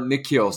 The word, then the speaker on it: N'Keal: but or Nikhil, sorry, not Kenil. What the hell is that? But Silent K. – Silent K.